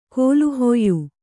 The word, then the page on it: ಕೋಲುಹೊಯ್ಯು: kōlu hoyyu